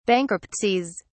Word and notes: bankruptcies: British English

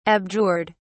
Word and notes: abjured: British English

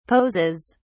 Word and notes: poses: British English

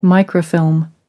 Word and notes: microfilm: American English

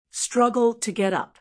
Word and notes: struggle to get up: American English

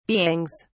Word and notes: beings: British English

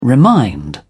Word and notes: remind: British English

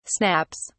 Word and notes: snaps: British English

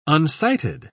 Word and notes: unsighted: British English

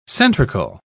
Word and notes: centrical: British English

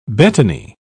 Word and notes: betony: British English

